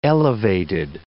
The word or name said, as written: elevated